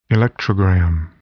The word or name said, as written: electrogram